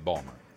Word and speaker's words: Baltimore: If you're from Baltimore, you say Ballmer. Hey, yeah, I'm from Ballmer.